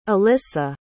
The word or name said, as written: alissa